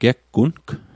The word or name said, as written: geggung